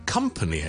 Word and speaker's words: company: 咁几时先读 come 咧？ company 系 come，OK，company 一间公司啦，company 或者一啲陪伴啦，我哋叫做 company 就唔会变成 company 嘅，OK，it's company。